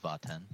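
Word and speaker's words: Vat'en: This one is Vaten. – Vaten, how do you spell that? – V-A-T apostrophe E-N.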